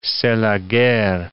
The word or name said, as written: c'est la guerre